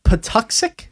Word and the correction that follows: Patuxet: That's the name of the tribe. It's Patuxet. – Yeah. – Patuxet, I think, is the way it's pronounced.